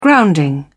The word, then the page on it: grounding: British English